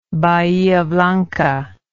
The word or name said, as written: Bahia Blanca